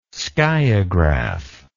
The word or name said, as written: skiagraph